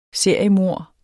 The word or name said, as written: seriemord